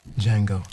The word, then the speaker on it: Django: Django. D-J-A-N-G-O. The D is silent. Django!